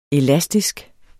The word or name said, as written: elastisk